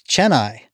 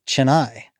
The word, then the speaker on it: Chennai: While we were recording this, I realized I had trouble pronouncing the name of the city in India, Chennai. It's Chennai, not Chennai, but now I know.